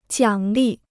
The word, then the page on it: 奖励: jiǎng lì